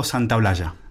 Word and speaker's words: Santaolalla: Gustavo Santaolalla. – C'est parce qu'il y a deux L à la fin et qu'il faut dire ja.